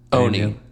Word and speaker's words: Oni: And how do you say the Oni. – Oni. – Oni. – Not Ani.